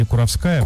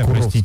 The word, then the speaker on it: Куровская: В электричке направления Москва-Куровская, или Куровская, Куровская, простите, да, если я ударение неправильно ставлю, безбилетник встал с места и перебежал от контролеров.